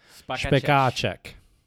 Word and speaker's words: Spekáček: So here's the difference. So it's only in word stress. So you're saying it the way we would say it in America. It's actually Spekáček.